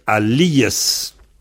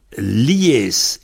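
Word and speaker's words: alïes: Alors, souvent, c'est alias. A-L-I, tréma, E-S. Je conseille de mettre un tréma sur l'I pour que vous vous souveniez qu'il faut dire alias. Enfin, la prononciation standard. Alias. Alors, en vente, en général, ça devient lies, lies.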